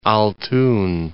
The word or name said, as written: Altyn